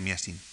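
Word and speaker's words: Massine: El ballet blanco de las coreografías de Petipa es revisado primero por Foquín y después por Miasin. Masin se suele pronunciar Miasin.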